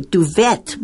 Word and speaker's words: duvet: No, it says D-U-V-E-T. So I would say duvet because, you know, there's a T there. – Well, but actually that is not right. Whenever you see this word, you never pronounce the T. It's duvet.